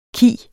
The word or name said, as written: khi